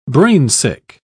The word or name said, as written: brainsick